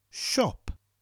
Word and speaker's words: siop: Shop. The only difference is in Welsh it's spelt S-I-O-P. And the S-H sound, the sh sound, comes from the S-I sound in Welsh. Shop.